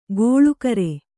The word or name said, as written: ಗೋಳುಕರೆ